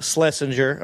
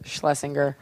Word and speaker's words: Schlesinger: We're sitting here with the very funny, talented, beautiful Eliza Schlesinger. Am I saying that last name right? – Nope, but that's okay. – How do you say it? – Schlesinger.